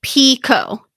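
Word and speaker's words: Picoult: Her last name is definitely pronounced Pico. That's a direct quote from Goodreads Jodi Picou Books Reading Group. – Got it. So her, the L and the T are silent. Picou.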